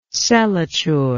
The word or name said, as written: celature